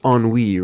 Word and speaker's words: ennui: Or like me, I said NUI for about 20 years until a friend one day said, "You know, it's pronounced ennui, right?"